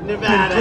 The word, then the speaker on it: Nevada: And you always have to say it correctly. Nevada.